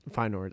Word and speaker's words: Feyenoord: Feyenoord, I believe I'm saying that right. – Yeah, I think Feyenoord's correct. – Feyenoord, I think that's how you say it.